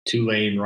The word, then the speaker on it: Tulane: Uh, we um, Tulane rhymes with rain, and they are from New Orleans.